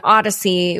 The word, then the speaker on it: Audacy: They'll all be out on Odyssey, which is a new app that I guess Exit Scam has partnered with, and it's spelled AUDACY.